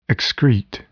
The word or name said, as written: excrete